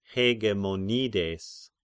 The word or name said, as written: Hegemonides